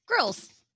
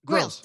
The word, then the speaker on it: Grylls: Grylls. – Grylls.